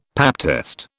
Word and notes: pap test: British English

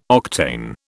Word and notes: octane: British English

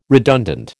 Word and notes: redundant: American English

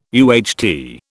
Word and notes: UHT: British English

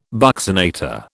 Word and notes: buccinator: British English

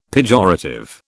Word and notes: pejorative: British English

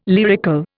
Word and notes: lyrical: Indian

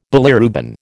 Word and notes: bilirubin: American English

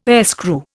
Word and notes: airscrew: Indian

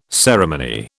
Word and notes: ceremony: British English

